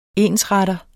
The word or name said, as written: ensretter